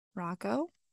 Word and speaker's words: Rocco: Rocco? Did I say it wrong again? – No, you got it.